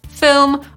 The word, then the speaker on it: film: No choćby po to, żeby zapamiętać, że ono ma trochę inną wymowę, no bo nie mamy w nim polskiej samogłoski i, tylko raczej y. Film.